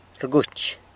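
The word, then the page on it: rguch: San Lucas Quiaviní Zapotec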